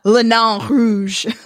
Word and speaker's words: Nain Rouge: Because I love saying, I love giving the true Frenchness to this word. Le Nain Rouge.